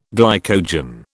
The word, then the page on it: glycogen: British English